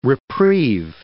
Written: reprieve

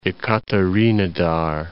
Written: Ekaterinodar

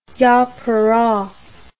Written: yapura

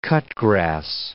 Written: cut-grass

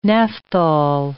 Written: naphthol

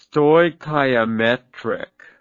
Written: stoichiometric

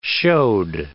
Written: showed

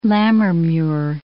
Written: Lammermuir

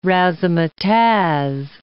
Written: razzamatazz

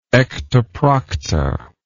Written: ectoprocta

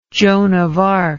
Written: Joan of Arc

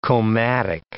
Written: comatic

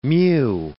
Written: mew

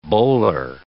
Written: bowler

